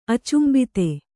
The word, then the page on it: ಅಚುಂಬಿತೆ: acumbite